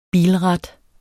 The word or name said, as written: bilrat